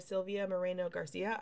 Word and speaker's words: Moreno-Garcia: Um, okay, so Mexican Gothic by Sylvia Moreno Garcia. I said it right this time.